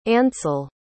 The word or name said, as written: ancil